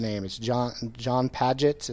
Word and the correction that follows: Piaget: is that it? – Piaget. – Yes, I butchered it.